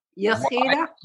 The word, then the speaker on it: Yechida: You spell it. Is it Y? Yechida? – Y-E-C-H-I-D-A. Yechida. – Thank you. – And that's C and H. That's C, phlegm, H.